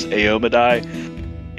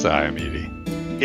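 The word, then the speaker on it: Iomedae: I think the correct pronunciation is Aomadai. It would be way easier to say Iomedi. – I actually have heard that the correct is Iomedi.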